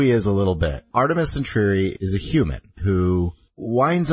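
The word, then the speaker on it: Artemis Entreri: Today we are going to be going over Artemis Intrary. Is it, how am I saying? Mm-hmm. Am I saying that is correct? – Okay, I'm like, am I butchering this? – Artemis Intrary.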